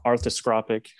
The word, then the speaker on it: arthroscopic: He's going to have arthroscopic. How do you say it? – Arthroscopic.